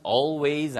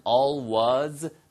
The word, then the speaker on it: always: 발음은 always 아니면 always 다 가능합니다.